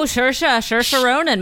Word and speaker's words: Saoirse Ronan: Saoirse Ronan? – Oh, Saoirse Ronan, my alter ego.